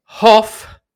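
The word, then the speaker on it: Hough: And Brady Hoff, that's the correct way to pronounce his name, defense mid, which I would have loved to know before bloody moving him on.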